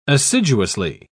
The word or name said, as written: assiduously